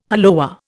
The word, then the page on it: hollowware: Indian